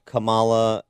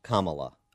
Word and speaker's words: Kamala: I've heard it's Kamala and Kamala. – Okay. – So it just depends on which syllable you want to put the accent.